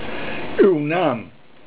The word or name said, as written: naam